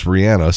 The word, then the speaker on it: Rihanna: And that is the correct pronunciation we're being told now. It's Rihanna, sort of like Rihanna. And people have been saying Rihanna all these years.